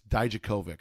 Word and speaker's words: Dijakovic: Jr. I always say his name wrong. It's Dijakovic.